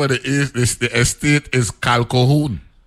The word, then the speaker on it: Calquohoun: Q-U-O. – That's the middle syllable. Yes. – C-A-L-Q-U-O-H-O-U-N. – Kalkohun.